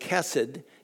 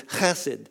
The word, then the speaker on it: chesed: We tend to just pronounce it chesed. It's okay, chesed. But the Hebrews, you got to clear your throat when you say it. It's chesed.